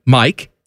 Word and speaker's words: Mike: My name, Mike, M-I-K-E, I say we don't spell it like that. I say we do M-Y-K-E.